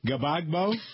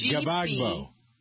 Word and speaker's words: Gbagbo: It's Gbagbo. Yeah. – Spell it again. – G-B-A-G-B-O. Gbagbo. – Yeah.